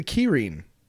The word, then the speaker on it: Kirin: This is the Kirin. Now, it's pronounced Kirin, but you might recognize it from the way we pronounce it, Kirin beer.